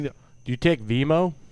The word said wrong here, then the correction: Venmo: I thought it's Venmo. – It's Venmo. Is it Venmo? – It's Venmo.